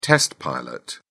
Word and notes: test pilot: British English

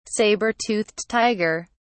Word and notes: saber-toothed tiger: British English

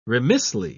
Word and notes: remissly: British English

